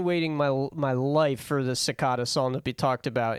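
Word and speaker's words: Secada: I believe it's Cicada. – Yeah, John Cicada. – It's definitely Cicada.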